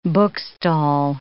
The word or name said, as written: bookstall